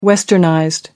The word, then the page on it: westernized: American English